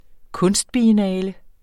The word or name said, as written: kunstbiennale